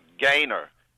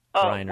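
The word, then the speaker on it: Griner: About Brittany Gaynor. – Greiner.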